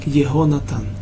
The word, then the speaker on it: Егонатан: И Йонатан везде пишется Йонатан. Юд, Вав, Нун, Тав Нун Здесь же впервые он читается как Егонатан.